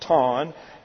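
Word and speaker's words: ton (τόν): When you use it with the definite article ton, as is the case here, it's actually pronounced ton, T-O-N, with the definite article used as a demonstrative pronoun, it is translated idiomatically.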